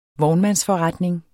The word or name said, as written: vognmandsforretning